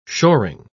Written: shoring

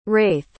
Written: raith

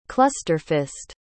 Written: clusterfist

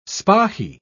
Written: spahi

